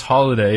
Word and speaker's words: Holiday: I had that trouble because my last name is Holiday and it's spelled with two L's.